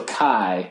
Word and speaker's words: chi: So there's this concept that I was looking at today, and correct me if I'm pronouncing it wrong, chi square fit. – Oh, okay. So chi.